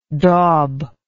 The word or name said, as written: dauby